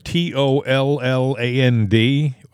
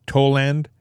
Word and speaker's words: Tolland: And when I was in Hartford, T O L L A N D, I thought it was Toland. It's Tolland.